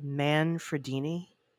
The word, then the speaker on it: Manfredini: I get that Henry Manfredini, did I say that right? Manfredini? – Yes.